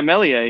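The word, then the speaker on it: Meslier: Melier? I don't pronounce the S. Is that what we're thinking? Is that correct? – Yeah. Yeah, Melier, yeah.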